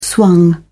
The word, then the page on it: swung: British English